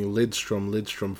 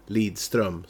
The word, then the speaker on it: Lidström: But then again, you've been calling Lidström for forever, and he's actually Lidström.